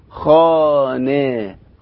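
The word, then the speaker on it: خانه: أما الهاء الغير منطوقة فهي التي تقع في آخر الكلمة فقط، ويكون نطقها مماثلا لكسرة خفيفة مثل كلمة خانة، خانة، أهي كلمة خانة، هنلاقيها خاء ألف وبعدين نون والهاء، هذه الهاء اللي احنا بنقول عليها هاء صامتة أو هاء غير منطوقة، عشان كده احنا لما بنيجي ننطق بنقول خانة، خانة، يعني النون كأن النون مكسورة.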